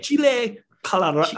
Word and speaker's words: chile colorado: It's called Chili Colorado, and I know you're supposed to roll your R. I'm not like Hispanic or anything. I'm white, super white. – Chile. It's Chile. – Chile Colorado.